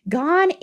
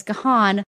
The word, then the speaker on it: Gahan: Gahan is the English way to pronounce it. It is the UK way, but then a lot of Americans have said Gahan, and you will hear it still as Gahan a lot.